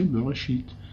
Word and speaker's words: Bereshit: Mais Bereshit ne peut être prononcé que si on met des points voyelles qui le font prononcer Bereshit.